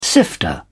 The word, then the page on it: sifter: British English